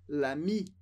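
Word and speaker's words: l'amie: Aqui bem importante, o som de o amigo e a amiga é exatamente o mesmo. Se fosse o amigo, ficaria l'ami. A amiga ganha um e no final, mas a pronúncia é exatamente a mesma.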